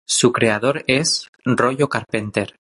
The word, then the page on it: creador: ispanu